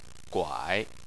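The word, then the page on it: guai: tone 3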